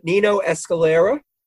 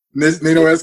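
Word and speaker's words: Niño Escalera: Listeners of this show know pronunciation is not my strong point, but Nino Escalera. – Nino Escalera.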